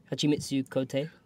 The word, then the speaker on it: Hachimitsu Kote: How do you pronounce this again? Hachimitsu Kote. – Yep, there you go.